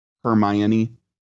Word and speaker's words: Hermione: He saw Harry and immediately began to eat Hermione's family. Did I pronounce that correctly? – Hermione, close enough. We all know who you're talking about. – How is it pronounced? – Hermione.